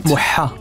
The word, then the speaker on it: Mohammed: In het Arabisch is Mohammed. Mo Mohammed. Moha. Moha. Van achter in de keel.